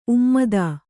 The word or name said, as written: ಉಮ್ಮದ